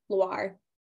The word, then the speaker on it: Loire: And the region is in Loire, L-O-I-R-E. – Loire. – Loire. Thank you. That's why I needed her to spell it so she could pronounce it. She's the French one here.